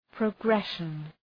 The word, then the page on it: progression: prə’greʃən